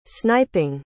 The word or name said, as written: sniping